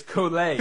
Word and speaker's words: Kolek: I mean, he might come out in 5 years and say it's Kolek. You know, like who right. knows? Like, it's just silent K at the end. It's Kolek. It's French.